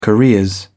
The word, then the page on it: careers: British English